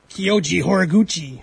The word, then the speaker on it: Kyoji Horiguchi: It's Kyoji Horiguchi.